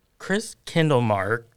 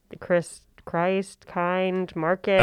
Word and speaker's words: Christkindlmarkt: Chris Kindlemark, and it's a mouthful. – you said it correctly, so I can tell you're a local because when these national media partners come in, they're like Chris Christ, kind, market, oh, really?